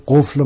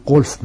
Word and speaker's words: قفل: این خیلی سواد چندانی هم نداشته چلبی، حتی یه جای قفل مثلا قلف میگفته، مولانا قلف گفته، از مولانا پرسیدن چرا قلف تلفظ میکنی، قفله، گفت برادرم چلبی قلف تلفظ میکنن، من مطابق او صحبت میکنم.